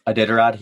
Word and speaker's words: Iditarod: I think called the Iditarod, or I forget how it's pronounced. – Iditarod, yeah.